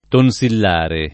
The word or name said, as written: tonsillare